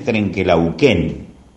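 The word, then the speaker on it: Trenque Lauquen: Pero la historia es que Trenquelauquen, eh, Laguna Redonda o Lago Redondo, así eh, significa Trenquelauquen, cuya verdadera eh, pronunciación es Trenquelauquen, pero, pero con el tiempo las verdaderas pronunciaciones se popularizan dándole otra tónica.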